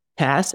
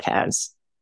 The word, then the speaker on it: Tas: And what I love is how you say Tess, as in SaaS, not Taz.